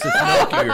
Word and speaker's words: gnocchi: I said it wrong on purpose. It's gnocchi, right? – I'm like, did he say gnocchi?